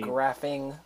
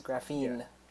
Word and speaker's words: graphene: So, for our third topic, we're going to go on and discuss graphene. So graphene, over the past like year or so since I heard of it. – Spell it out for them. So it sounds like graphene. Graphing, but it's graphene.